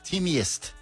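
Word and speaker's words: Teamgeist: Sí, Team Geist o Geist. – Se pronunciaría Teamiest. – Teamiest. Okay. Significa espíritu de equipo en alemán.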